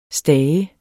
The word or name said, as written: stage